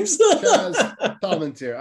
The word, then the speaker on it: Palminteri: Am I saying this right? Chaz Palminteri. – Yeah, you're the Italian. I mean, I'm a little, right? Little Italian. You're more Italian than I am. – Chaz Palminteri.